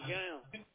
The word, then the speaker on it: McGowan: First name is Bobby and my last name is Gam. – Will you spell it for me? – First name is B-O-B-B-Y and my last name M-C-G-O-W-A-N.